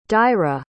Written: dáire

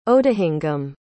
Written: odahingum

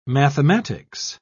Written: mathematics

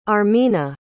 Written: armina

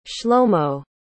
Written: shlomo

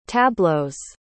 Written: tableaux

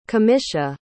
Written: camisha

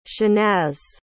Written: shahnaz

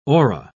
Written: ora

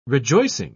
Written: rejoicing

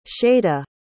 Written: shada

